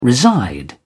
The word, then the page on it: reside: British English